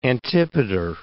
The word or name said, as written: Antipater